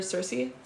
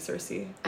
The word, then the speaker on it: Circe: It sounds so weird saying Circe. – I know. – I just think Kyriki. – In Greek, it's Kyriki, so I never know how to say it in English.